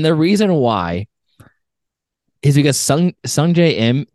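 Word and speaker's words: Sungjae Im: I don't even know how to say it. Sung Im? Sung Young Im? – Sung Jae Im.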